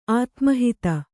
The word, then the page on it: ಆತ್ಮಾಹುತಿ: ātmāhuti